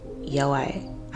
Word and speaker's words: Yaoi: It is pronounced Yaoi.